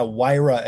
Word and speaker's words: Huayra: Huayra, as if it were wire, W-I-R-E. – Oh, so the H is silent.